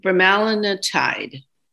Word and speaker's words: bremelanotide: Brem, bremelanotide, B R E M E L A N O T I D E.